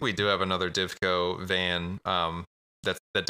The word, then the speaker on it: Divco: It's like a Dineco or whatever milk truck. I'm probably saying that wrong. – Yeah, Divco. – Divco truck.